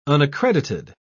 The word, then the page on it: unaccredited: British English